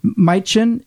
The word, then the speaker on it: Majcen: Last name is spelled M-A-J-C-E-N. Majcen, I think is pronounced.